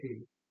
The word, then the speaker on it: Hoeh: Dr. Hay. I don't know how. His name is spelled H-O-E-H, but that they used to pronounce it Dr. A, permanently.